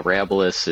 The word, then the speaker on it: Arabilis: So is it Arabilis? – Yep. Yep. Oh, you nailed it. So not many people do, but we answer to a lot of it, but yeah, Arabilis is how we've been pronunciating it, and it's from the Latin word Arabilis, and so that's the Latin meaning farmable, right, or arable land.